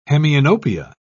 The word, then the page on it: hemianopia: British English